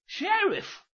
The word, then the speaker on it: Sheriff: 哦哦，想起来了想起来了，叫雪瑞夫 Sheriff。对啦，就是他。这雪瑞夫原是由两个古英文字合起来念成的，头一半叫 Shire，后一半是 Reef。